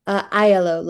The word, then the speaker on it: Aiello: Aiello, like I yellow.